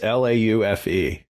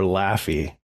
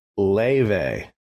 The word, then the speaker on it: Laufey: So her name, now I was mispronouncing it wrong. It's Laufe. So you want to call her Laffy, right? But it's Leve. Leve, because it's Icelandic.